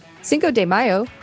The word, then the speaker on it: Cinco de Mayo: Cinco de Mayo, pronounced Cinco de Mayo in Mexico,